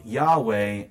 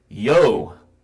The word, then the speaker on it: Jove: Now, I want to say that there are some people out there trying to say that the word Jove, which is the ablative case of Jupiter, is pronounced Yahweh in Greek. That is simply not true. Not true. I asked two separate experts in classical Latin, and both of them said, no, it's Yo, as in Joe.